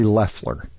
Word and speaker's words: Loeffler: So, by the way, it's Kelly Loeffler. You keep calling her Loeffler, but it's Loeffler. – It's Loeffler? – Yes, it is. – What's spelled Loeffler? – The O is silent.